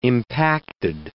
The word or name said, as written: impacted